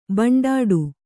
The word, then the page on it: ಭಂಡಾಟ: bhaṇḍāṭa